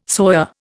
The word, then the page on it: soya: Indian